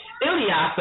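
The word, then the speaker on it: Ilyasah: So the next show that we have coming up next week, we're going to have Ayasa Ilyasa. I want to make sure I keep pronouncing her name correctly.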